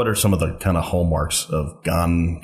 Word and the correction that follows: Ghanaian: Ghanaian. – Ghanaian culture. – Yeah. – There it is with that syllable.